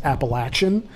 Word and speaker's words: Appalachian: I mean, I've only recently discovered that it's actually pronounced Appalachian. And that's going to be a hard bit of pre-programming to turn off in that way.